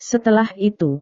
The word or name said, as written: setelah itu